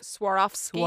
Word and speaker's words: Swarovski: How do you pronounce Swarovski? – Swarovski.